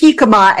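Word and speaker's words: jícama: Michael is wondering if the Mexican turnip is a jacam. Wait a minute, I'm mispronouncing it. – It's jicama.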